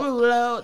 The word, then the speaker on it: Derulo: Derulo. That's it. That's his name. That's how you say it.